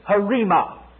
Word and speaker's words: rhema: This word, here in the Greek, means harema. It's spelled R-H-E-M-A in the Greek, but it's pronounced harema.